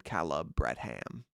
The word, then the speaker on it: Caleb Bradham: That's how he pronounced it. He said Caleb Bradham.